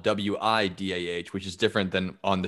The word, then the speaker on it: Whydah: They spell it differently. The ship is spelled Whydah, and the town is spelled Ouida, but it's pronounced the same way, Whydah.